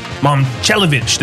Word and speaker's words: Momcilovic: Milan Momcilovic. Did I say that right? It's hard to pronounce his name. – I think so. Got it pretty close.